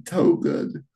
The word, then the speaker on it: Towgood: That was close with the name. I've gotten some much worse pronunciation, so that wasn't all too bad. It's Sean Togood.